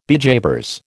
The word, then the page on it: bejabers: American English